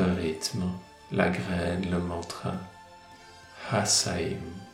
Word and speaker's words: Hasaim: Hasaim. H-A-S-A-I-M. Le H se prononce comme une expiration.